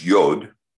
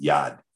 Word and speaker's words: yod: Now, so you see this Yod, Yod, and the Yod is the closed hand. It, and it should not be pronounced yod at all. It should be pronounced yad, yad, which means hand.